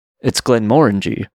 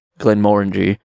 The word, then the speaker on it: Glenmorangie: It's Glenmorangie. And the reason I know that it's Glenmorangie is because I found an ad for it, and I heard the pronunciation by Daphne's father himself, Brian Cox.